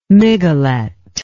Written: miguelet

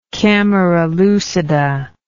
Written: camera lucida

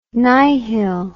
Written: nihil

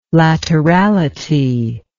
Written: laterality

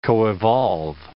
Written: coevolve

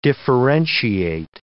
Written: differentiate

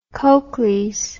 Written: cocles

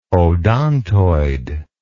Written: odontoid

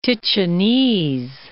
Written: Ticinese

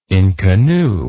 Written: inconnu

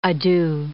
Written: adieu

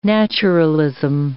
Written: naturalism

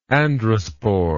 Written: androspore

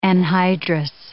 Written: anhydrous

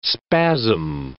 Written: spasm

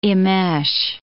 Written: immesh

